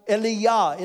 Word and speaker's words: Elijah: The name Elijah, they would have pronounced Eliyah. It sounds like Eli, the word for my God in Jesus' language.